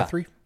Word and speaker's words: Guthrie: Guthrie? – Yeah. – Is that how you say it? – Yeah.